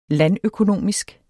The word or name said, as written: landøkonomisk